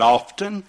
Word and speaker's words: often: Let me ask you, how do you pronounce O-F-T-E-N? And I said, often. She said, wrong. I said, well, what is? She said, it's often. She said, don't pronounce the T.